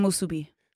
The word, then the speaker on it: musubi: Oh, you said a little different. Yeah. I was like, I learned something. – Musubi. – Musubi. – Musubi. – Emphasis on the mus.